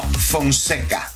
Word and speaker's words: Fonseca: All right, if you want to get connected, Julio Fonseca, am I saying it right? Francesca? – No, Fonseca.